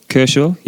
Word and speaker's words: Kershaw: Kershaw, yeah.